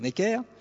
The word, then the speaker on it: Necker: Pour essayer de faire baisser la pression populaire, Necker, le ministre de Louis XVI, alors à l'époque on prononçait Necre, mais c'est une prononciation qui s'est perdue aujourd'hui, donc je vais dire Necker,